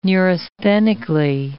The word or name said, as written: neurasthenically